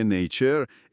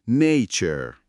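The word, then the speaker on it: nature: مثلا تاکید میکنه که توی نیچر استرس روی هجای اوله. نیچر.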